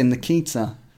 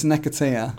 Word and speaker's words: Nketiah: So I always say Nikita. But it's Nketiah.